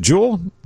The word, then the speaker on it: Juul: Next, Juul, is it Juul? J-U-U-L is the pronunciation? – Yes.